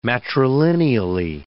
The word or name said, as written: matrilineally